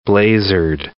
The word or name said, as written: blazered